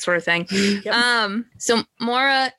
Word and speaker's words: Maura: So Mara, I'm not sure how her name is spelled. M-A-U-R-A. – Maura. – Okay. – Yeah, I always say Maura in my Maura.